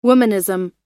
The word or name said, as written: womanism